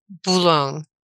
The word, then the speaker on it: Boulogne: It's like Boulogne. – Okay. – Like cologne with a B.